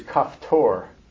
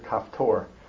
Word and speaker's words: Caphtor: The Hebrew word is kaftor. How you spell it is up to you. C-A-P-H-T-O-R. It's a Hebrew word, kaftor, which is unusual enough, but this word's going to appear later in the chapter.